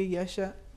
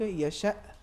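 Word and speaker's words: يشاء: مثال کے طور پر میں عرض کرتا ہوں خدمت میں، لفظ یشا، اگر ہم پڑھیں گے یشا، حمزہ بالکل ضعیف ہو گیا ہے. اگر نبر کے ساتھ اگر ہم تلفظ کریں ہو جائے گا یشا، حمزے کی آواز آنی چاہیے.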